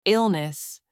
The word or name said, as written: illness